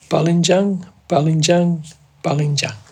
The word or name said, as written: balindjang